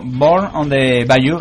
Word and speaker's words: Born on the Bayou: Pues bueno, vamos a acabar con esta canción, como ha dicho Ramiro, Born on the Bayou.